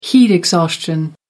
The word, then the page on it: heat exhaustion: American English